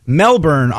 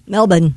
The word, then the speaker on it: Melbourne: Melbourne, Australia, I guess it's Melbourne. – Melbourne.